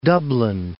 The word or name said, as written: Dublin